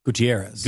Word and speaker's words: Gutierrez: How do you say his name? Or is Gutierrez. – Gutierrez.